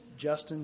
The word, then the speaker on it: Justin: It's Justin Shelton. It's J-U-S-T-I-N. Shelton, S-H-E-L-T-O-N, and I'm the manager for Springwood FHP-LP, which is the owner of the Fairfield Inn and Suites.